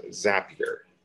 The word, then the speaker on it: Zapier: Another popular tool that's out there in the market is Zapier. Rhymes with happier, so to keep that in mind, because some folks call it Zapier.